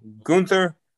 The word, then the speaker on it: Gunther: It is now Gunther. That is how you say that, with the German pronunciation.